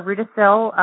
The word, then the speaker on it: Rudacille: And Laura Rudacille, I'll spell it real quick because it's an odd back name, Rudacille.